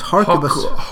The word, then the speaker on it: harquebus: Muskets and, how do you pronounce it, harquebus.